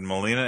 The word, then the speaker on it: Molina: Alfred Molina.